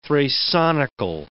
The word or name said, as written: thrasonical